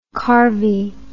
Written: carvie

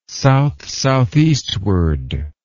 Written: south-southeastward

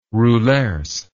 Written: roulers

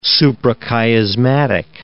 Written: suprachiasmatic nucleus

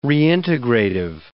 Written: reintegrative

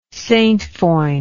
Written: saintfoin

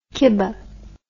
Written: kibbeh

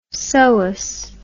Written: SOAS

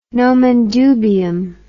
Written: nomen dubium

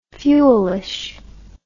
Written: fuelish

